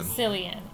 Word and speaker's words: Cillian: Cillian. And so, for some people, it can be Killian. It's just how you choose to pronounce it.